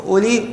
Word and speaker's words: أولي: هذه أولي وأولائي، أولي وأولائي التي هي اسم ماذا؟ اسم إشارة. لاحظوا معي حين تنطق أولي، ما نطقت هذه الواو، ما قلت أولي ولا قلت أولائي.